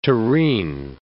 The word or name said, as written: tureen